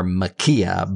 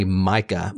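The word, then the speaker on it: Maika: We have been pronouncing her name wrong. I've been switching the K and the I and calling her Makia, but her name is really Micah Monroe.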